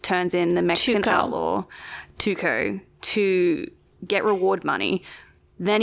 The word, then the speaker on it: Tuco: Tuco, form an uneasy partnership. I hope that's how you say his name. I'm sure it's not. – It sounds okay. Tuco.